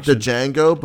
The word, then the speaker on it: Django: Actually, I was gonna say Django, but the Django might be a good one. – Yeah. No, I think the best motherfuckers come from Pulp Fiction. – The D is silent.